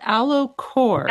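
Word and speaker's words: Ellacor: So if you do a couple AlloCores, you might get a similar effect to having a mini lift. – That's called AlloCore.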